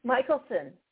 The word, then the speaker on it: Michelson: Michelson.